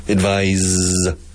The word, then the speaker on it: advise: Advice sempre singular. Advice, advice, advice, advice. Con C-E al final. Como sustantivo, como verbo con S-E. Advise. And the pronunciation changes.